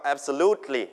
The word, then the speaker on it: absolutely: absolutely พยางค์ที่ 3เน้นนะครับ absolutely